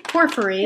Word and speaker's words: Porphyry: And in particular, like the purple stone, and I might pronounce this incorrectly again. Porphyry. – Yeah, that sounds great. – Cool. Porphyry stone.